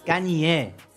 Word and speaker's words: Kanye: es Kanye. Kanye. Kanye. – Así hay que pronunciarlo. Perdón. Mucha gente dice Kenny, ¿no? – Claro, Kanye.